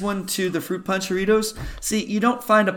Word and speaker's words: Jarritos: And please tell us what you thought of the pineapple Jarritos. what Dom, you say it. You can roll your R's. Jarritos. There you go.